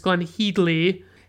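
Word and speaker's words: Glenne Headly: And then we have Glenn Headley, which, by the way, is apparently how you pronounce her name. It's Glenn Headley.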